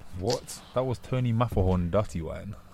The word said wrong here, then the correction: Matterhorn: You didn't say the name right, but okay. – Is it not Mafferhorn? – No. – Matterhorn? – Yeah.